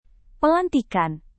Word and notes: pelantikan: Indonesian

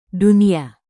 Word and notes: dunia: indonesian

